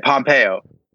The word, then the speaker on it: Pompeo: But what I was going to go to was what Mike Pompeo or Pomp, whatever the however you say his name, yeah, Pompeo, right? – I like Pompeo.